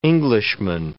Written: Englishman

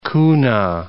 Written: kuna

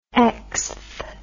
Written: xth

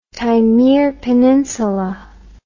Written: Taymyr Peninsula

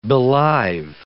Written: belive

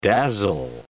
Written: dazzle

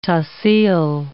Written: tahsil

